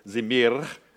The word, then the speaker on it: zimir: Actually, zimir is the way you pronounce that. That's best I can do, but that's pretty close. Zimir.